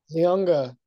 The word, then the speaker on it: Leonga: I think there's a G in there, a silent G. Or no, a invisible G. – Lefau? – Leonga Lefau. – Oh, Leonga Lefau. Oh, yeah. I say Leona like a lion.